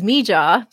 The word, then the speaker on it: Mija: So the first pronunciation is Mija. Those are her first two letters of her first and middle name. But also, so if you spell that out, M-I-J-A, it's pronounced Mija.